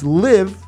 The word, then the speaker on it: Liv.e: All right, before that, we had B Kool-Aid, B period C-O-O-L hyphen A-I-D, featuring, all right, it's Live, but it's spelled L-I-V dot E. The only reason I know it's Live and the E is silent is because I saw it on their Instagram page. Jimetta Rose, shout out to Jimetta Rose, J-I-M-E-T-T-A space R-O-S-E, super dope artist, and V.C.R.